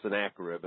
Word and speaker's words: Sennacherib: So we have here according to the biblical rendering here, the pronunciation is Sennacherib, and that's what I go by.